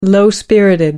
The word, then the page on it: low-spirited: American English